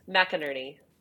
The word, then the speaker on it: McInerney: I say that right? – Close. Yeah. – Well, why don't you say it? – McInerney.